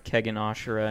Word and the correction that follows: Kengan Ashura: Can you just say it correctly? Kengan Ashura. Or Ashura. No, you have Kegan. Me saying it wrong now. Kengan Ashura.